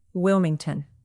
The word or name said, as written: wilmington